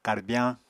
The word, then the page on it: karbyañ: Apatani